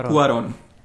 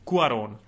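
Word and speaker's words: Cuarón: Eh, no jak se to vyslovuje? Ku- Alfonso Cuaron. – Cuaron.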